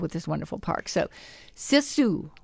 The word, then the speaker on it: sisu: And that's one thing I also have learned from the Scandinavian culture is, and you pronounce it S-I-S-U. I want to hear the way you pronounce it. – The Suomi Hall? – No, oh. S-I-S-U. – Oh, sisu. – Sisu.